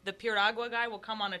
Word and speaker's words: piragua: In New York City, in some of the Latin American neighborhoods, they'll have piragua, which is sort of the same thing. It's like a And how do you pronounce it? – Piragua. – Piragua? – Piragua, yeah.